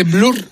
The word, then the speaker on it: Blur: ¿Esto se dice Blur o cómo se dice? – Blur.